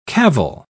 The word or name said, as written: cavil